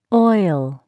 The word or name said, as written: oil